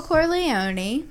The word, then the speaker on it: Corleone: Michael Corleone or Patrick Bateman. – You know, there's you don't pronounce the E on that one, though.